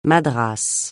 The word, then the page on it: madras: francais